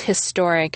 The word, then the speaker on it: historic: If you have an odd accent for an American and pronounce historic as historic, you can make an argument for writing an historic. But it's a stretch, since the standard American pronunciation of historic is with the H sound.